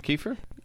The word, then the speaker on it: Kiefer: He keeps saying it wrong. – Oh, Kiefer. Isn't it Kiefer? – Yes, but you said Kiefer again. – Oh, like Ether.